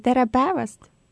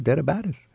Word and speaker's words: tere päevast: Tere või tere päevast. – There we go, tere päevast.